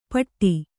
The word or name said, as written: ಪಟ್ಟಿ